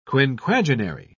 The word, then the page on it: quinquagenary: British English